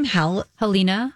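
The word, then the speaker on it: Halyna: Helena.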